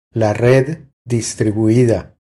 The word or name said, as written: La red distribuida